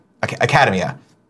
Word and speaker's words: Academia: Love the anime, My Hero Academia. Academia. That's how you say it.